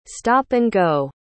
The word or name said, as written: stop-and-go